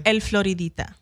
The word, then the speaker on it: El Floridita: El Floridita.